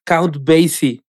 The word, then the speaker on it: Count Basie: Es Count Basie. – No, ah, Count, Basie. – Count, Count, Basie.